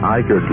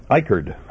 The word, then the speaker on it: Icard: WUIV, Eichert, Lenore, Boone, a USA radio affiliate. – Yep, Eichert. I wouldn't have guessed right. I-C-A-R-D, by the way, North Carolina.